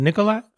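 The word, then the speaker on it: Nikola Jokić: How do you say his name? Jokic. Nik- yeah, it's Jokic, but it's Nikolai Jokic. Is it Nikolai or Nikola? Nikola, Nikola Jokic. It's, it's, it's Nikola it's spelled Jokic. Nikola, right?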